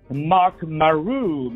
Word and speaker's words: Marc Marut: His name is Marc Marut.